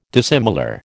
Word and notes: dissimilar: American English

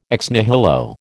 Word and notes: ex nihilo: American English

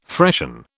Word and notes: freshen: British English

